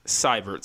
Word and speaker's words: Seibert: Am I saying Seibert? Sorry. – Yes.